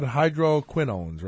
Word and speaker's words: hydroquinones: All right, so you want to talk about hydroquinones, right? – Hydroquinones. Is that how I yeah, that's how you say it.